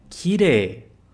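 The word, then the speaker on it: kirei: Então, kire. Kire. Tá? Como você viu nas aulas anteriores, a gente vê que esse som de EI costuma ter som de EE, tá?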